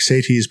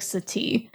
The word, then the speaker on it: Satie: Your topic is Erik Satie's performance indications. Do you know if I pronounced that right? – You didn't. – Okay. – It's Erik Satie.